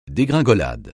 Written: dégringolade

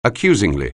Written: accusingly

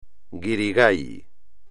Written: guirigay

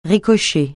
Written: ricocher

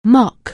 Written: mock

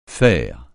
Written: faire

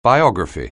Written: biography

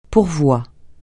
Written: pourvoi

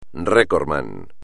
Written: recordman

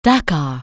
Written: Dakar

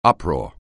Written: uproar

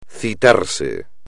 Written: citarse

